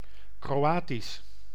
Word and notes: croate: [kʁɔ.at]; France

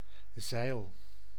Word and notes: voile: France; IPA: /vwal/

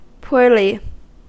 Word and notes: huonosti: [ˈʍuonosti(ʔ)]

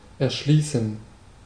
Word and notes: develop: IPA: /dɪˈvɛləp/

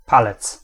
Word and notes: doigt: /dwa/; /dwɛ/

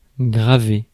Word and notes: graver: France; [ɡʁa.ve]; French

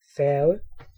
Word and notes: ferry: /ˈfɛɹ.i/

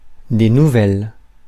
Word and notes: news: /n(j)uːz/; American English; [njuːz]

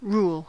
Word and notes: rule: American English; /ruːl/

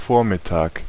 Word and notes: Vormittag: /ˈfoːɐ̯mɪtaːk/

